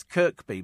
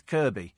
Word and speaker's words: Kirkby: Yeah, it's a silent K, isn't it? You think it's Kirkby, but it's Kirby.